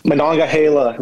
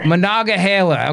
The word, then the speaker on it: Monongahela: how do you say that river? – Monongahela River. – Monongahela, okay, has been especially impacted, yes, by this influx.